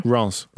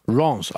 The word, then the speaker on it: Rennes: And we can say that Rennes are a good side. – How did you pronounce it? – Rennes. – Rennes.